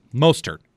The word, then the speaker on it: Mostert: How hard do we have to hit the T in Mostert? It's the question. – Right. – Of the day. – I don't think it's a silent T. It's a very soft T, though. I think I've got that right.